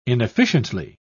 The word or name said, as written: inefficiently